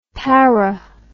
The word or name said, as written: pari